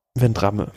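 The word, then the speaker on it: Vendrame: Ja, aber also war denn Andrea, wie spricht man aus? Italiener? – Vendramme. – Vendram? – Vendramme.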